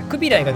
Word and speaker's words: クビライ: クビライがどちらかといったら正しい発音らしいで